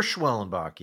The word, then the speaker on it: Schwellenbach: Yeah, the matchups look like Nola Fried, Suarez, Schwellenbach. Am I saying that right? – Spencer Schwellenbach, yeah.